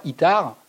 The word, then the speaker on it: Itard: Il faut savoir qu'aujourd'hui, quand vous allez à l'Institut des Sourds Muets, à, rue Saint-Jacques, à Paris, et que vous prononcez le mot d'Itard, Itar, dans la tradition de, l'histoire de la surdité, est considéré comme un monstre.